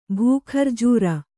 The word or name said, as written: ಭೂಖರ್ಜೂರ